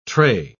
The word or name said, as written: trey